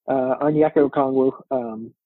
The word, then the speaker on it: Onyeka Okongwu: Is the other big that you have, and I'm struggling with this name, is it Onyeka Okongwu from USC, the other one we're looking at here? – Yeah, you said that right. Onyeka Okongwu.